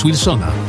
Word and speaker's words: Wilsona: Przypominamy, że poprawna wymowa to plac Wilsona z polskim W na początku.